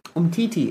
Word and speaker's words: Umtiti: You pronounced it incorrectly, Dave. Sorry. Umtiti is his name. Do you want to try again? Say it after me. There's two syllables. Three syllables, actually.